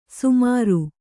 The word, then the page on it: ಸಮರು: Kannada